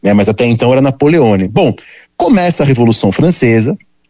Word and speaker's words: Napoleone: E o Napoleão, né, vocês sabem, tinha sotaque, corso, e ele não era Napoleon ainda, era Napoleone, né, ainda tinha uma pronúncia italiana,